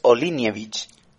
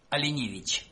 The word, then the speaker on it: Olinevich: pero es que es muy gracioso porque los bielorrusos la E hacen como una IE, entonces es Olinevich. – Alinevich.